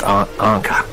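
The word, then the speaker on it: Encke: I used to call it Inky. It's E-N-C-K-E, but it's actually pronounced Anka.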